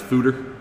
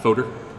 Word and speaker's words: fooder: Also, I learned that it's pronounced fooder. – Yep. – Not foder.